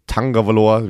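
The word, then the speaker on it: Tagovailoa: Ja, Tangavaloa, wir hatten ja wieder das Problem, dass Leute dann sagen, warum sprecht ihr ihn mit einem N aus? Hat er selber gesagt, so, so wird, er ausgesprochen. So wird er gesprochen.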